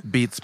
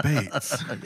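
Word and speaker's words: Beetz: And it's spelt Beats, but it's pronounced Bates.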